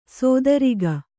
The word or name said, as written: ಸಾತಿರೇಕ